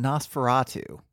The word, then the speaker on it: NOS4A2: Well, it's spelled N O S 4A2 by Joe Hill and why why I think it's pronounced Na- Nosferatu is that they they did change the name to Nosferatu in the UK, I think to account for differences in how that word would be pronounced over there.